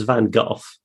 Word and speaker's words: Van Gogh: It used to be, when I was a lad, it was Van Gogh. And then I think people became very pretentious about the pronunciation of foreign names. So it then became Van Gogh,